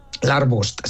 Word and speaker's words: Larbust: Lo he dicho bien, ¿no? Larbust. – Larbust, sí, correcto.